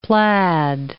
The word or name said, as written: plaid